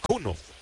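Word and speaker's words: Kuhnel: Joe Cuno, el nombre de este pitcher y apellido, Joe de Joel, Joe, y con K, Cunel, se escribe, se pronuncia Cuno.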